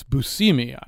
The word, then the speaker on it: Buscemi: It's Buscemi.